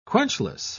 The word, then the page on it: quenchless: British English